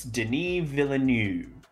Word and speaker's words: Denis Villeneuve: And then his last name looks like Villeneuve, but just say Villa, and then the N-E-U-V-E just kind of falls off. So, it's Denis Villeneuve. It's like you don't pronounce the V, it just kind of softly fades out.